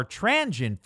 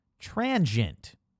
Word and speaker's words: transient: The shorter, more transient version is usually the righteous one. Yep, transient, two syllables, not transient with three.